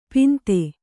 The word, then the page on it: ಪಿಂತೆ: Kannada